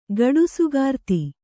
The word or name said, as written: ಗಡುಸುಗಾರ್ತಿ